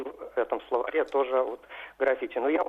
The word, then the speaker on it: граффити: Вот на портале Грамота.ру когда-то вот задавались вопросы, и были ответы, что вот только граффити нужно произносить. И когда-то в одном словаре, по-моему, это был большой толковый словарь Кузнецова, там приводилось ударение на первом слоге, и вопрос был в том, почему в разных словарях по-разному.